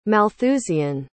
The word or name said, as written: malthusian